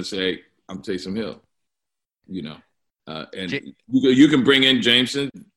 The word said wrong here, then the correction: Jameis: You can bring in everybody. I'm going to compete. He's going to compete for it. And I say his name wrong. Jameis Winston. Yes. Great.